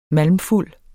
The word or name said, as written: MALMFULDE